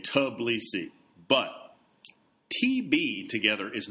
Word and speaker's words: Tbilisi: Should it be Tbilisi, as she heard it said by a journalist she knew who had a Georgian boyfriend, or was it pronounced with a tiny shred of a T sound flickering ahead of the B, like Tbilisi, as Russians the author met in Moscow called it?